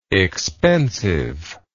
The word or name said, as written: expensiveness